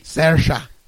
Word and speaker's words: Saoirse: How do you pronounce Saoirse? – It? Swarcy? – Saoirse. – Saoirse.